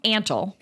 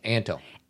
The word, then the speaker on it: Antle: So first of all, we were saying his name wrong. Antle. – Antle.